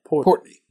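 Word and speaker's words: Portnoy: My favorite Barstool, I've told you about Dave Portier. How do you say his last name? – Portney.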